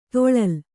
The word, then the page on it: ತೊಳಲ್: toḷal